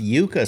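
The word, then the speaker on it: yuca: It's pronounced Yuka, says the beautiful and elusive Robo Kitty.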